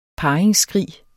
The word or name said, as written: parringsskrig